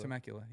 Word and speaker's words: Temecula: Is that how you say it? Temecula? – Temecula, yeah.